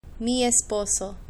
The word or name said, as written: mi esposo